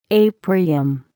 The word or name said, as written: aprium